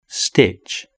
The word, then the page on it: stitch: British English